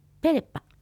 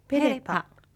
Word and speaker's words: ペレパ: 割るという意味のペレパ、ペレパ。 – R はちっちゃいので小さく発音しましょう。